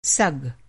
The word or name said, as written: सग